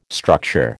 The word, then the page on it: structure: American English